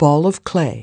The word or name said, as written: ball of clay